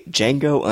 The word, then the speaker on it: Django: This is episode number 32 of Cinema Fix, focused on the movie Django Unchained. The D is silent, in case you haven't heard.